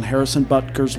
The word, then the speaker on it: Harrison Butker: I think they will rely too much on Harrison Butker's leg and will be unable to cover the 6.5 points.